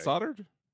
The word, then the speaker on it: soldered: Is that soldered? Is that actually soldered? – Yeah, it's soldered. That's soldered. Oh. Yeah. A circumstance. – Soldered. Yeah. I guess in good old English, the L is silent.